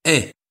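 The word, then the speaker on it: Aÿ: à Aix, ça s'écrit A-Y, c'est en Champagne, c'est à côté de Reims, c'est pas très loin d'Épernay non plus.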